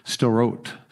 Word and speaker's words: Sderot: It's kind of hard to pronounce it. S-D-E-R-O-T, Sderot, Sderot.